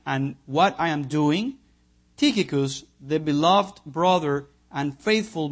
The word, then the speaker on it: Tychicus: How do you say it? Tychicus or Tychicus? Tychicus. Thank you.